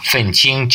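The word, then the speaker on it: фэнчинг (愤青): Читается оба вот фэнчинг, четвертый, первый тон.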